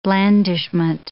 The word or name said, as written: blandishment